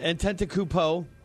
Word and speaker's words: Antetokounmpo: Antetokounmpo? – Antetokounmpo.